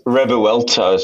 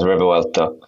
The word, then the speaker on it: Revuelto: It's pronounced Revuelto, so they say it really fast, Revuelto.